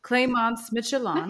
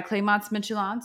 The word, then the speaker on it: Clémence Michelon: We're excited to announce to you guys that our next book club pick is The Quiet Tenant by, hang on, I had to look up how to say it, Clémence Michelon. – Nice. – Look it up. I said it pretty damn good.